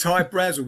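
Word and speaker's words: Hy-Brasil: There's Hyatt Brazel, High Brazil, people pronounce it High Brazil, and it's yeah, Brazil, okay. – Yeah. But it's High Brazil because that's how the Irish would pronounce it, because it's an, okay.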